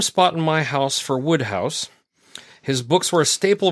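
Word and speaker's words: Wodehouse: Small nit to pick, his name is pronounced Woodhouse.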